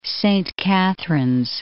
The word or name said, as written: Saint Catharines